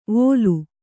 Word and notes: ವೊಲು: kannada

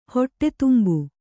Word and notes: ಹೊಟ್ಟೆತುಂಬು: kannada

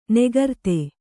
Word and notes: ನಿಕೃತ: Kannada

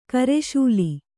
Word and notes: ಕರಿಸಾಲು: kari sālu

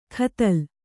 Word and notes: ಖತಲ್: khatal

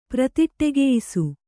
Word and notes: ಪ್ರತಿಟ್ಟೆಗೆಯಿಸು: pratiṭṭegeyisu

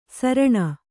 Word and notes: ಸೌರಣೆ: sauraṇe

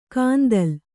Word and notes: ಕಾಂದಲ್: kāndal

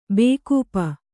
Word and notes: ಬೇಕೂಪ: bēkūpa

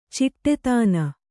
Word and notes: ಚಿಟ್ಟೆತಾನ: ciṭṭe tāna

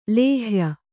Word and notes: ಲೇಹ್ಯ: lēhya

